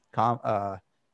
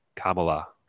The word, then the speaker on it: Kamala: For you and for anybody else listening, it's comma, like the punctuation mark, and la, Kamala.